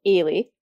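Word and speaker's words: Eilidh: So my name's pronounced Ailey, and it's a Gaelic name. So, I mean, phonetically, it makes no sense to anyone that doesn't speak Gaelic.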